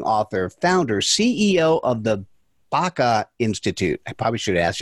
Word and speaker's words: Baca: It is Baca, and it's potentiation process.